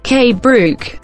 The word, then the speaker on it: KBruch: K. Brook. K. Brook. Bueno, en inglés se pronuncia así, K. Brook.